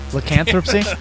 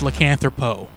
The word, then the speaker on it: lycanthropy: That's not how you say it. – I'm pretty sure that's how you say it. No. – Lycanthropy. – No, it's lycanthropo. – No, it's lycanthropy. – No, lycanthropo. – Like lycanthropo. – Lycanthropy. – Yeah, lycanthropy.